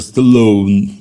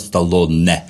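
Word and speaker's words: Stallone: Вот, например, какой-нибудь Эл Капоун. Который у нас Капоне. – Да, а так он Капоне. Или Сильвестр Сталлоун, да, который у нас Сталлоне.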